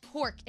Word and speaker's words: Knork: This is called Kork. It's a silverware brand. It's spelled K-N-O-R-K.